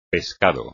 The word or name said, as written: pescado